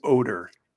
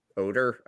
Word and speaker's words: Odor: It's pronounced Odor. – Odor. Oh, is it? I said Odor. – No, it is Odor. – It's not stinky.